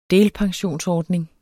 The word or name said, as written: delpensionsordning